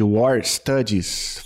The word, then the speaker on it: War Studies: tá lá no departamento de War Studies. Falei certo? – Falou certo.